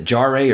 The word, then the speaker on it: Jarre: It was written by Kevin, I don't know if he pronounces it Jarre or Jar, but it's J-A-R-R-E.